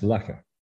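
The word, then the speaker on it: Loughor: That's L-O-U-G-H-O-R. But in the vagaries of pronunciation, that's pronounced Lucca.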